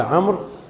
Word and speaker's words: عمرو: قال وعن عمرو بن شعيب، بعض طلاب العلم ينطقون عمرو بالواو والضم ومدري ايش، هذا الكلام مو صحيح هنا. وعن عمرو بن، والواو هذه يؤتى بها للتفريق بين عمرو وعمر، وإلا تأتي عليه الحركات كلها. اسمعوا، يتحدث عن شخص عمرو، ما بصحيح، ما بعمرو وعمر. رأيت عمرا، مررت بعمر، فالواو إنما لا تنطق، إنما يؤتى بها للتفريق بينه وبين عمر.